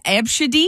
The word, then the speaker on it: Abcde: That's how it's pronounced, Abshidi. – Abshidi. – And it's spelled A-B-C-D-E, Abshidi.